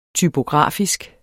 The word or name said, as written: typografisk